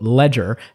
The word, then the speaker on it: Legere: So there were lots of people, I'm sure, have been wondering sort of, how do you pronounce the guy's name? Is it Laguerre? Is it Ledger? He had a tweet a while ago that is, I know there's been some questions about it. It's pronounced, quote, Ledger, as in AT&T is about to jump off the ledge-er.